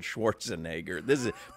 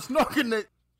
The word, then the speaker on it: Schwarzenegger: Crowder was trying to say Arnold Schwarzenegger. Play that one, Solana. – And Arnold Schnorkenegger. – I don't even, like, it's so impossible to mispronounce it that way.